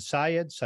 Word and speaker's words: Syed: This was the person, am I pronouncing his name correctly? Is it Syed? – I believe it's Syed.